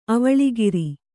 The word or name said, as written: ಅವಳಿಗಿರಿ